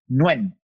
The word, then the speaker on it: Nguyen: I never know how to pronounce N-G-U-E-Y-N. – Nguyen. – I never know how to pronounce the last name. Nguyen.